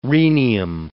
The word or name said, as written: rhenium